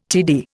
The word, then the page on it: TD: Indian